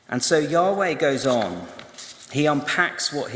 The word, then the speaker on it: Yahweh: And in some translations, that name will be in capital letters, which is translated from the Hebrew word, which is spelt in capital letters, YHWH, which through translation over time gains some vowels and becomes Yahweh.